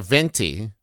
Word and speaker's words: Venti: Like a Venti. – Like a Venti, 20 Venti, Living Large. – Because it's 20. – 20 Venti. – Living Large. – Living Large. – Huh. – Venti rhymes with 20.